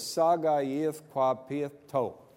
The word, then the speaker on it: Sagayithquapiato: And phonetically, his name is Sagayithquapiato.